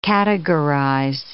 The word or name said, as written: categorize